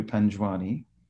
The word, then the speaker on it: Panjwani: Well, traditional, the Indian way would be, they pronounce the J's, so it'll be Raju Panjwani.